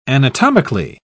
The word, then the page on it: anatomically: British English